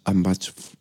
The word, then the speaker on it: Hambach: Si tecleas Ambach, o sea, Ambach Forest, se escribe H-A-M-B-A-C-H.